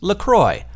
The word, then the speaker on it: St. Croix: But in fact, LaCroix is made in America by the St. Croix River, which is spelled C-R-O-I-X, and is pronounced Croix. So, yep, it's America, it's not French. And they say on the website, it says right there, it's pronounced LaCroix, like enjoy.